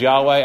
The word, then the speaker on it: Yahweh: And his name is Yahweh. That's the way we pronounce it. Some would pronounce it Jehovah, but a more proper rendering is Yahweh.